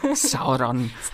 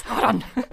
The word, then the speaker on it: Sauron: Es klingt zumindest, aber wer rollt sonst so das R, wenn Sauron ausgesprochen wird? – Numenor. – Sauron. – Sauron.